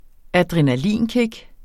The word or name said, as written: adrenalinkick